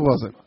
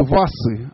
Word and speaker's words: Васа: Однако, в соответствии с общими правилами, название иностранное произносится так, как оно произносится на оригинальном языке. Поэтому в настоящий момент в России принято говорить об ордене Васы, а не ордене Вазы.